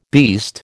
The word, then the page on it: beast: American English